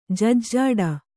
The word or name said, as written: ಜಜ್ಜಾಡ